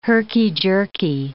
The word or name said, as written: herky-jerky